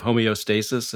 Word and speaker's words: homeostasis: Why do you think particularly in the West, we seem, you know, you talk about the Western idea of homeostasis, if I'm pronouncing that correctly.